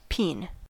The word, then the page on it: peen: American English